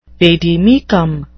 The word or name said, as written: vade mecum